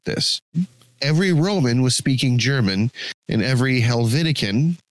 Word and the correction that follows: Helvetian: is that how we should pronounce that, Helvetican from Helvetica? – Helvetian.